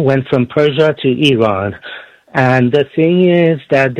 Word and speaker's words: Iran: I was there, guest of yours from Idaho, it's pronounced Iran, not Iran. Like, we don't say Italy, we say Italia.